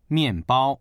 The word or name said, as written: mian4bao1